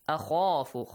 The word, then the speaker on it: أَخَافُ: أَخَافُ. خَا has a heavy sound. Make sure you pronounce it correctly.